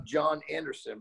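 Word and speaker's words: Jon Andersen: And it's at the John Anderson. But keep in mind, my name is spelled different. So J-O-N. And A-N-D-E-R-S-E-N.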